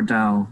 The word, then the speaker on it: Tao: But every time I say Tao, I think of or Tao, like it's pronounced more like doll, and that makes me think of the school of thought that's associated with it.